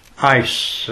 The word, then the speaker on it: eis: The Greek word there is eis, as the way it's pronounced. It's E-I-S.